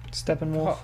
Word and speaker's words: Steppenwolf: Steppenwolf.